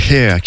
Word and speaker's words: H31R: Oui, Hair, qui s'écrit H31R, mais qui se prononce Hair,